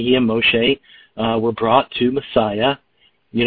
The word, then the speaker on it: Moshe: I use the name Moshe because that's how it's pronounced in Hebrew.